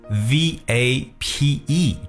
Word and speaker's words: vape: V A P E 这个词，vape，记住这个单词在读音的时候，特别注意一下字母 v 的发音呢，是需要咬住嘴唇的。